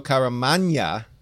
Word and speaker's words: Caramagna: and letters by VC's Joe Caramagna.